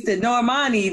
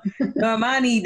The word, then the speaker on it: Normani: Normani, there we go. Normani, don't she have like some awards and shit? – I ain't gonna lie to you.